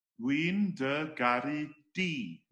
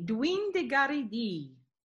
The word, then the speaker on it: Dwi'n dy garu di: Dwi'n dy garu dwi. Did I say that right? – Dwi'n dy garu di. – Di. Dwi'n dy garu di.